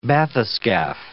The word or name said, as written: bathyscaphe